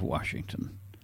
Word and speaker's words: Washington: Washington. And there is an R in Washington for all those who don't pronounce it that way.